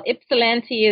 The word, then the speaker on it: Ypsilanti: Ypsilanti? – You did, you did very well. Ypsilanti is, yeah, it's a beautiful little town.